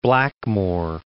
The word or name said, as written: Blackmore